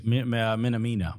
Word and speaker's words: Minamino: Minamino.